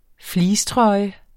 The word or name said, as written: fleecetrøje